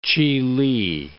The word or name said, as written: Chihli